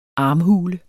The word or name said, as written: armhule